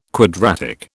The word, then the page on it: quadratic: British English